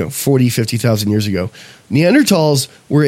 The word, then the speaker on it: Neanderthals: Neanderthals, which is how you say it correctly, Neanderthals were actually...